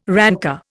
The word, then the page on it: rancour: Indian